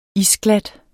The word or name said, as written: isglat